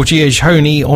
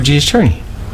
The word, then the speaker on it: Giacchino: Yes, Mission Impossible 3 with Michael Giacchino on musical duties.